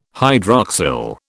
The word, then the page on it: hydroxyl: British English